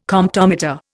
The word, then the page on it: Comptometer: Indian